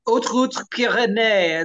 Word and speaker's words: Haute Route Pyrénées: The HRP is the Haute Route Pyrénées. It's the Pyrenees High Route. It's spelled with an H. It's a French pronunciation there.